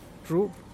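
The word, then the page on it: zhub: Teotitlán del Valle Zapotec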